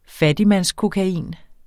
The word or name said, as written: fattigmandskokain